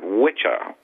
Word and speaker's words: Wicca: And as some people may know, the word witch, it comes from the word witcha, which is W-I-C-C-A, which most people mispronounce as Wicca.